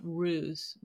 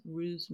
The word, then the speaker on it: ruse: Well, they say the pronunciation could either be ruse with like a Z sound or ruse with an S.